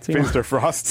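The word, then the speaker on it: Finsterforst: Après ça, Finster Forced, qui, ça aussi, un genre de truc que j'aurais voulu peut-être prononcer autrement. – Finster Frost. – C'est ça.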